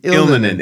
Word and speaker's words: Ilmanen: And I always mispronounce his name. So forgive me, Mr. Ilmanen, Ilmanen. Terrible pronouncing. – Ilmanen. – It's a really difficult name. – Ilmanen, yeah.